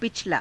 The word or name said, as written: pichalaa